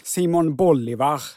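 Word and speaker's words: Bolívar: Simon Bolivar, ja, det tror jag han ska heta som i boll.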